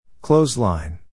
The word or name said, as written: clothesline